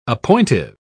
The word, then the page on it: appointive: British English